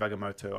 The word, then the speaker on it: Agamotto: I know it's the Eye of Agamotto. I said it wrong.